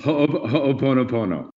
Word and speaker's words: Ho'oponopono: And I'm sure I botched the pronunciation of Ho'oponopono. Is Jonathan Hammond. – For what it's worth, no one says it right, so don't worry about it. – How do you say it? Ho'oponopono.